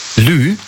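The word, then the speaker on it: lieu: Lü. Also Lü, das schreibt man also L-I, E und dann U. Lü.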